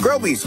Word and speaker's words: Groby's: Groby's, where the accent is on grow.